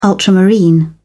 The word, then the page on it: ultramarine: British English